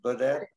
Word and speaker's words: Goded: Maya Goded, how do you pronounce her name? Goded? – Yes.